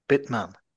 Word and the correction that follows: vitamin: No. V like Victor. – Oh, V-I-T-A. – Oh, vitamin.